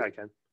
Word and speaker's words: Taycan: My thing is, I remember watching MKBHD's autofocus on, I think it was the Porsche Taycan, or no, mm-hmm. Am I saying that right? Taycan.